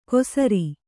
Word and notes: ಕೊಸರಿ: kosari

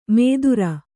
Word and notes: ಮೇದುರ: mēdura

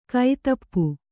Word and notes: ಕೈತಪ್ಪು: kannada

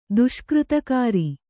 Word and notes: ದುಷ್ಕೃತಕಾರಿ: kannada

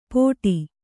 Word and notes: ಪೋಟಿ: kannada

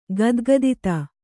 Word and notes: ಗದ್ಗದಿತ: kannada

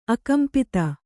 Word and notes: ಆಕಂಪಿತ: ākampita

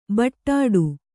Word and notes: ಬಡ್ಡು: Kannada

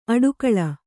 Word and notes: ಅಟ್ಟುಗೂಳು: Kannada

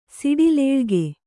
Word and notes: ಸಡಿಲಾಳ್ಕೆ: saḍilāḷke

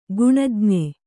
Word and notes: ಗುಣಜ್ಞೆ: guṇajñe

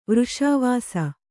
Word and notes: ವರ್ಷಾವಾಸ: varṣāvāsa

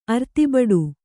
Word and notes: ಅರ್ತಿಬಡು: Kannada

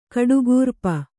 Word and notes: ಕಡುಗೂರ್ಪ: kaḍugūrp

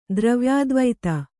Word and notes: ದ್ರವ್ಯಾದ್ವೈತ: dravyādvaita

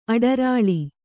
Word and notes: ಅಡರಾಳಿ: Kannada

